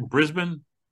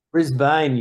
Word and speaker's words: Brisbane: Is that, did I say it right? Brisbane? – Brisbane, you say.